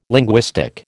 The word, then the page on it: linguistic: American English